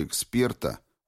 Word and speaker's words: эксперта: В моем случае среди раздающих награды ни одного эксперта, ударение на втором слоге, специалиста в предмете конкурса не было, а следовательно группу этих уважаемых лиц нельзя было называть словом «жюри».